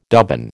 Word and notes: dubbin: American English

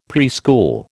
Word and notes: preschool: American English

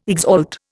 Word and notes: exalt: Indian

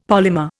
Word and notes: polymer: Indian

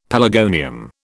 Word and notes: pelargonium: British English

